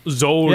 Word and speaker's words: Zolder: W Series and DTM are in Zoldner. – Ooh, very good. – Zoldner. Oh, there's no N there. It's just Zolder.